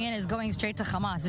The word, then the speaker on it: Hamas: and I do pronounce the KH in mm-hmm. Hamas. – Hamas.